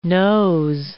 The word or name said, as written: nos